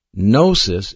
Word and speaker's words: gnosis: One of those words is spelled G-N-O-S-I-S, and it's pronounced gnosis. The other word is spelled E-P-I-G-N-O-S-I-S.